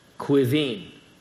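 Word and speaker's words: Caoimhin: A-O-I-B-H-E-A-N. A-O-I-B-H-E-A-N. It's pronounced Yvonne. How would you pronounce this boy name? C-A-O-I-M-H-I-N. C-A-O-I-M-H-I-N. Quivine.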